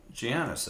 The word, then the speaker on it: Giannis: Giannis, that's Giannis. How you pronounce it. It's Giannis? – Yeah.